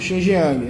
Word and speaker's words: Xinjiang: E aí depois a China foi tentando corrigir é, esse problema, adotando uma política é, de combate ao terrorismo em Xiangyang e é, calma, ah, desculpa, Xinjiang, né? Beleza, estou falando errado, suave.